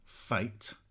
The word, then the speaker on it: F8: Their new album, F8, pronounced Fate.